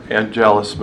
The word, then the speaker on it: Angelus: So again, one of the major efforts for the Billings Rotary Club was this idea of a war angelus movement. And basically a gentleman by the name of, did I say it wrong? – Yeah. – Angelus, thanks.